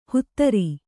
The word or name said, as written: ಹುತ್ತರಿ